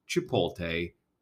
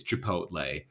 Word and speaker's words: Chipotle: It's not Chipotle. It's Chipotle. You know what? This is a short segment. It's quick. I don't need to spend any more time on it. You're saying it wrong.